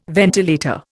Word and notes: ventilator: Indian

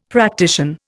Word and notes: practician: Indian

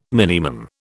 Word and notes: minimum: British English

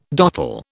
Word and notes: dottle: British English

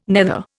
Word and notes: nether: Indian

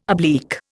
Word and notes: oblique: Indian

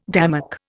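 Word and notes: démarche: Indian

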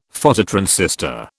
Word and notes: Phototransistor: British English

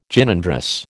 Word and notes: Gynandrous: American English

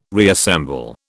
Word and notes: reassemble: British English

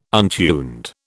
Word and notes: untuned: British English

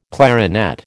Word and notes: clarinet: American English

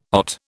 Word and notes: OT: British English

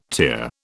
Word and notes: tier: British English

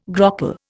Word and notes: grockle: Indian

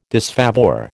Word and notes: disfavour: American English

